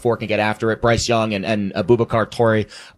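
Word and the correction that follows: Traoré: Traore, I always mispronounce his name.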